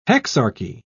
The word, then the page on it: hexarchy: British English